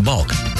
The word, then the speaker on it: Bulq: We're Bulk, spelled B-U-L-Q, but pronounced Bulk.